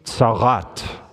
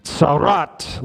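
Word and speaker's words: tzaraat: Say it with me, roll your R real good. Tzarat. Tzarat.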